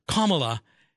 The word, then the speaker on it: Kamala: The correct pronunciation of Kamala, which means lotus flower in Sanskrit, is Kamala, as the emphasis on the first syllable, sounding like Kamala.